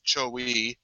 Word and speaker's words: Chogui: Chowi. And it's spelled C-H-O-G-U-I.